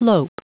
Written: lope

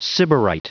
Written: sybarite

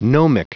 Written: gnomic

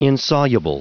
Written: insoluble

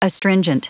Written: astringent

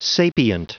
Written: sapient

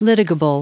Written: litigable

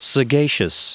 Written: sagacious

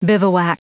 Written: bivouac

